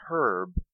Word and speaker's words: herb: The H in herb is silent in American English.